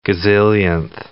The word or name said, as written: gazillionth